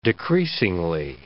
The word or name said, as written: decreasingly